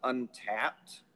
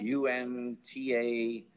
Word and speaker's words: Untappd: You can follow us on Untappd, okay. – UNTAPPD. So it's spelled a little different, but it's an app, Untappd.